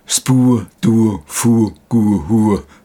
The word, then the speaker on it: SPDFGH: April, Kim Bowers, australische Musikerin, Musikdirektorin und Klangkünstlerin bei der australischen Rockband, geschrieben SPDFGH, ausgesprochen Spu Du Fu Gu Hu, habe ich extra recherchiert.